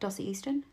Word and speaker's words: Dossie Easton: Dossie Easton? – Yeah, you got it right.